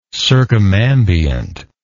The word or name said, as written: circumambiently